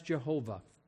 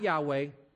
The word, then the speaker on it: Jehovah: Of course, the overarching name is the one Yud-Heh-Vav-Heh, the tetragrammaton, they call it in Hebrew, but it's pronounced as Jehovah, not Yahweh, Jehovah. Yahweh is the distorted version of it that has come down for some reason, and it just drives me crazy because when you talk to the Jews, they don't like to say the name.